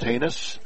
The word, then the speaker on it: Hanes: Very good bunter is Haynes, spelled H-A-N-E-S, pronounced Haynes.